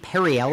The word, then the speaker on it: Perriello: their one of their board members is Tom Perello. I believe I'm pronouncing that correctly. Hey, yeah, I think it's Perry. I think Perriello.